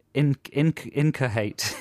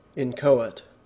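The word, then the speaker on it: inchoate: Incoate. Incoate. – No H in there.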